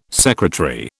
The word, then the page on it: secretary: British English